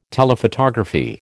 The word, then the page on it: Telephotography: American English